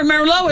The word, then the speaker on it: Merlot: Yeah, remember Merlot is, and that T is silent the T on the end of Merlot.